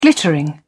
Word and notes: glittering: British English

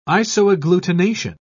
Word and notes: isoagglutination: British English

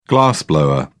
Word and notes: glassblower: British English

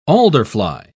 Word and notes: alderfly: British English

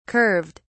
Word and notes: curved: British English

